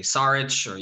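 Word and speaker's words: Šarić: I never pronounce his name correctly. Saric. – You got it right.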